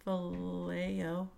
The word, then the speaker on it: Vallejo: Do you pronounce the L's? – Vallejo. It's like, let me think about that. Yes, I do.